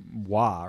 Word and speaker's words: Roy: and he's French-Canadian. So his name is technically, he said Derek Wah, or however they say it, but he's like, it's all right. You know what I mean? Like that's, right. That's how they pronounce it here.